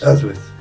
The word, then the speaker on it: Auschwitz: Auschwitz, sí.